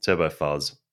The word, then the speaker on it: Turbo Fuzz: Oh, what did I say? – Turbo Fizz. – Sorry, Turbo Fuzz. There's a U.